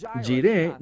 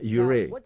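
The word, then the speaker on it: Jireh: Ahora, Jiré no es la forma correcta de pronunciarlo. Es la palabra Yure.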